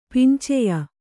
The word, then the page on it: ಪಿಂಚೆಯ: pinceya